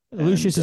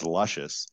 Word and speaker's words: Lucius: Sounds, I mean, if you just look at it the way it's spelled, it looks like Chaz Luscious, which kind of sounds like a adult film star's name.